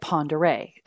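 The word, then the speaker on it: Pend Oreille: Well, after we were there for 3 days, we went to Farragut State Park in Lake Ponderay, and it's spelled Pendoriellel. It looks like panned oriole.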